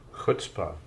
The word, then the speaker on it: chutzpah: How is it pronounced? Chutzpah? – Chutzpah.